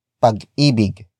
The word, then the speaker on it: pag-ibig: And that is pag-ibig.